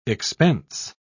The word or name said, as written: expense